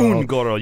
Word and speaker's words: Un'Goro: Like most of Hearthstone's expansions, is it Un'Goro? Is a reference. – Un'Goro, yep. – Is it Un'Goro? – Un'Goro. You gotta say it like an orc.